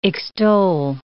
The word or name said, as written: extol